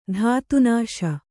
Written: ಧಾತುನಾಶ

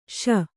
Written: ಸೋ

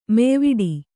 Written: ಮೆಯ್ವಿಡು

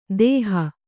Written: ದೇಹ

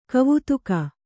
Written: ಗವೇಧುಕೆ

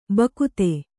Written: ಭಕ್ತ

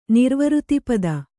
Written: ನಿರ್ವೃತಿಪದ

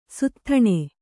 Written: ಸೂತತನ

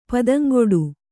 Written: ಪದಂಗೆಡು

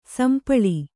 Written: ಸಂಪಳಿ

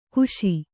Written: ಖುಶಿ

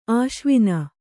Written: ಆಸವನ

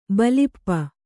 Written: ಬಲ್ಪು

